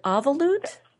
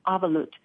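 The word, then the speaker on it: avelut: How do you pronounce that? Is it avolut? – Yes, you said it just right. It's a Jewish term. Well, it's from the Jewish tradition. It's a Hebrew term, avolut.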